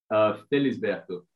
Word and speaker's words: Felisberto: Felisberto. So it's actually Portuguese origin.